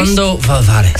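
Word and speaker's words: Cuándo volverás: Right? Cuando volverás. – Sorry, beautiful, beautiful pronunciation.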